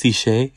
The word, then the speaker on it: cishet: It's actually pronounced "siche."